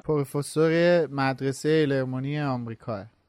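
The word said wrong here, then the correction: Ilvermorny: ایلورمونیه که ما اون وقتی فکرم اشتباهم تلفظ کردیم هممون.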